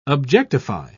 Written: objectify

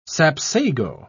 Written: sapsago

